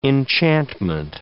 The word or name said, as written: enchantment